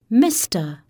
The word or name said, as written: Mr